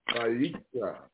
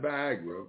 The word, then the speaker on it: Vayikra: We're going to turn to the first book of Vayikra. Not Viagra, Vayikra. And it's spelled V-A-Y-I-K-R-E.